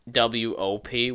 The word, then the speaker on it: wop: But, like, W-O-P, which is pronounced the same as W-A-P, is without papers, W-O-P.